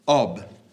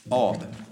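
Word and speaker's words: Ab: Last of all, number 10 is Ob, meaning Father. It's spelled simply A-B. Ob.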